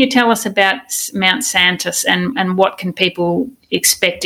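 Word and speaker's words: Säntis: Now, I'd like to ask you a bit about, and excuse me if I pronounce it incorrectly, but Mount Santis. Now, it's a mountain. – Very good.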